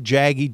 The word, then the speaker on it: Jaggy: It's getting started here, but it's called What Would Jaggy Jeff Say? Now, that's spelled J-A-G-G-Y, and my last name is M. Bowden, I-M-B-O-D-E-N.